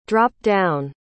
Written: drop-down

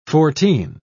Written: fourteen

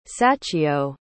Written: sachiyo

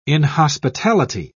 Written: inhospitality

